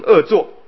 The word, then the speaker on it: 恶作: 毁心所呢又名恶作，好，一看到恶作有这个恶这个字，那照理说它应该是恶的啊，不是啊，我们看看恶作的恶不应该念作恶，是念作恶啊，念作恶，这个厌恶的恶，恶作就是厌恶自己先前所做的意思啊，就是讨厌啊，刚才这么为什么那样做。